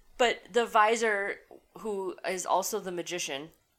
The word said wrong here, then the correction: vizier: You mean the vizier? – Is that how it's pronounced? – That's what they say in the movie. You said visor, like the thing that poker players wear. – You're correct. I did mean vizier.